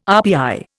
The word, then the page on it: RPI: Indian